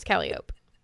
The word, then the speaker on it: Calliope: Is that pronounced Calliope? – Yes. Mm-hmm. Okay. Yeah, it is Calliope. – I mean, who would see that word and think Calliope, honestly? – Literally me and Tim.